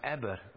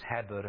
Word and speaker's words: Eber: But to the grandson of Eber, which we could also pronounce as Heber, and we get the word Hebrew from it.